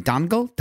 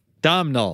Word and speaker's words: Domhnall: Domhnall.